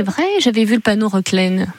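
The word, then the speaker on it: Reclesne: On prononce Reclaine ? – Reclaine, oui. On ne prononce pas le S.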